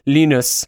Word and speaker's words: Linus: pronounce correctly with the German pronunciation, Linus.